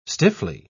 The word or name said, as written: stiffly